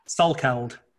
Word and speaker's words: Salkeld: Salkeld. – Salkeld. – Okay. – So pronounce with a soft O.